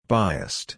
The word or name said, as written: biased